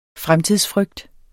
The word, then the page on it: fremtidsfrygt: [ ˈfʁamtiðs- ]